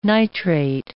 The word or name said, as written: nitrate